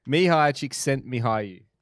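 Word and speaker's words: Mihály Csíkszentmihályi: We're doing a guy called Mihai Chick Sank Mihai or something like that. – Mihai Chick Sent Mihai. – Did you do the little YouTube? – I did. – This is how you pronounce it.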